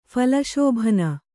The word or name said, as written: ಫಲಶೋಭನ